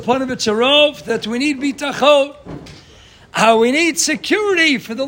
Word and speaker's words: Bitachon: A Bitachon is when you pronounce it with the tam milara. That means on the second syllable. Bitachon."